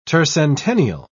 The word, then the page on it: tercentennial: British English